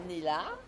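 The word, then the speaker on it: Nella: Alors, je m'appelle Néla.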